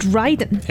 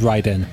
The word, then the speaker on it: Raiden: In Japanese, as far as I know, we've watched a lot of anime, so it should be pronounced Raiden. – It should be pronounced Raiden.